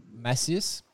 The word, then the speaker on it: Macias: One you'll hear coming up here on our interview this week for our updates for our injured riders, and I've been saying this wrong, I think, in the interview so far, but it's Luis Macias. I'm not very good. My Aussie accent doesn't work too well on a Spanish pronunciation, Benny.